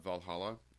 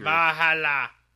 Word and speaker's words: VA-11 Hall-A: The game is called Valhalla, Cyberpunk Bartending or something like that, but it's spelled VA-11 Hall-A. So it's like set in the future. – Valhalla!